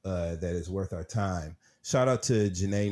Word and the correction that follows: Janai: Norman, Janai Norman, I believe is how you say her name. Janai. Uh, ABC News announced Janai Norman as the new co-anchor for Good Morning America Saturday and Sunday broadcast.